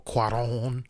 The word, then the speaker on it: Cuarón: Alfonso Cuarón.